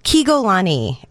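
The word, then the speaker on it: Kiegolanee: Anyway, I went to Kegolani, and I know that's a mouthful. – It's say it again. – Kegolani, and okay. I'll spell it for you. It's K-I-E-G-O-L-A-N-E-E, and basically it's the phonetic spelling of a small town in the state of Oaxaca in Mexico.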